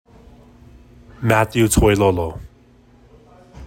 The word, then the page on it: Matthew Toilolo: MAH thyew TOY low low